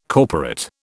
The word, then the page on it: corporate: British English